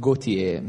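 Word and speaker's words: Gotye: Allora, ci ascoltiamo una canzone recente del 2011 di Gautier, si pronuncia così, perché anche se è scritto in modo diverso, ricorda il nome Gaultier francese, è scritto Gautier però con la Y.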